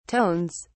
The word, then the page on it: tones: British English